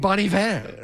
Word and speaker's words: Bon Iver: How do you pronounce it? – Bon Iver. – Oh, you're right.